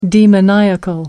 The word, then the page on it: demoniacal: American English